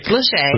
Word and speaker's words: Lachey: Nick Lachey. That's how you pronounce his name correctly, right? Nick Lachey. – Lachey. – Yes.